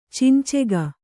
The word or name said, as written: ಚಿಂಚೆಗ